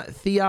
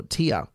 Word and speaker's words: Thea: It features vocals by American singer Thea Austin, or Tia, depending on how you say it.